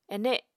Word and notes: ene: Apatani

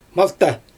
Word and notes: malta: Xyzyl

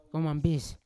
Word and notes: kaumambis: Yawa

